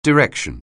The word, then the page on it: direction: anglais